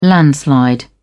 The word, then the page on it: landslide: British English